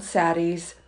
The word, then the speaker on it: saddies: They are called saddies, by the way. Rhymes with baddies, but imbues the notion with a bit of compassion.